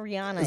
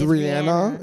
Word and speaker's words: Rihanna: It's not Rihanna. – It's Rihanna. Rihanna.